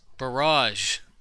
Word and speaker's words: barrage: How the fuck do you say barge? – It? – Barrage. – You mean barge? – I can't even say it now. – Not barge. – Barrage. A barrage. It's a two-syllable word.